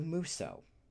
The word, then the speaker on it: Musou: and that is known as the Muso. Uh, it's called out because it's spelled M U S O U in English.